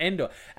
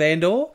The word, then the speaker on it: Endor: Yes, two very important vowel sounds there between Andor and Endor. Actually, Ash, who you work for on your breakfast show, I'd love to hear him pronounce those. Given that he pronounces, like, when you talk about Ellen DeGeneres, he pronounces Ellen as Alan. So I feel like instead of saying the Battle of Endor, he'd probably say the Battle of Andor.